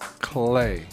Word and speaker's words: HLE: And so for people who are not familiar, you spell your name H-L-E. – Yeah. – But it's pronounced Clay.